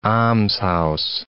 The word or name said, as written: almshouse